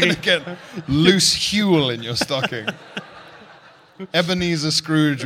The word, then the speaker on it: Huel: Huel? – Yeah. Yeah. It even rhymes with gruel.